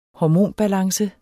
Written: hormonbalance

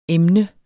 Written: emne

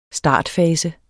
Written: startfase

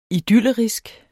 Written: idyllerisk

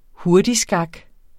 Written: hurtigskak

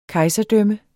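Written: KEJSERDØMMET

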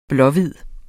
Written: blå-hvid

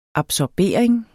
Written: absorbering

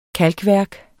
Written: kalkværk

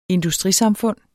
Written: industrisamfund

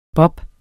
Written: bobs

